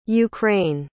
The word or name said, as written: ukraine